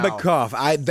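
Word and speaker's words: Bekmambetov: You're just stringing syllables together now. Bekbombekov. – That is spot on. It's Timur that I didn't hit quite right.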